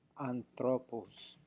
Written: anthrwpous

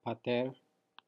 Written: pater

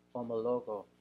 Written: omologo

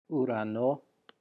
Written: ourano

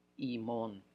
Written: imon

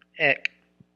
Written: ek

